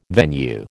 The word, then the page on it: venue: American English